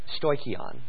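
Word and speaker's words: stoikion (στοιχεῖον): The Greek word for elements, stoikon, okay, we would spell that S-T-O-I, stoi, S-T-O-I, C-H, and then E-I-O-N. So S-T-O-I-C-H-E-I-O-N, stoikion.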